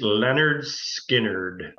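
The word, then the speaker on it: Lynyrd Skynyrd: Pronounced Leonard Skinnerd.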